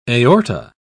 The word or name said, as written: aorta